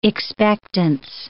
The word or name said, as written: expectance